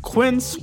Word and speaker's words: Quince: Today Explains support today comes from Quince, which rhymes with since, but is spelt with a Q-U.